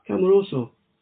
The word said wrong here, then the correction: Casemiro: Casemiro, sorry.